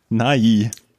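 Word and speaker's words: Nighy: Der Fernsehmoderator heißt Bill Nye. – Ja. – Und der Schauspieler aus Großbritannien, der hier Scrimger spielt, der heißt Nai. – Ja, Bill Nai.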